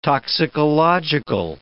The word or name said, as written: toxicological